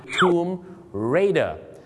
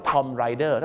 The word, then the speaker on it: Tomb Raider: Tomb Raider แต่บางคนนะครับไปออกเสียงว่า Tom Rider